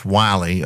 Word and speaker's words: Willie: Because Jones was an opponent of Wiley Jones is spelled like Willie, but pronounced Wiley, was a longtime prominent resident of Halifax, and he was North Carolina's leading opponent of the federal Constitution.